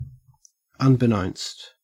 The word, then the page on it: unbeknownst: Australian English